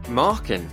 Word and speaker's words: Markin: Markin. It's marking your homework without the G.